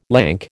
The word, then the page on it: lank: American English